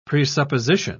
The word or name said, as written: presupposition